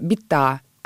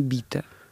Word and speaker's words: бита: Правильно говорить «бита», а не «бита».